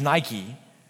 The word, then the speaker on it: Nike: So the Greek word of victory is Nike. You say it a little bit different phonetically, but that's what it is.